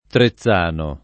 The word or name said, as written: Trezzano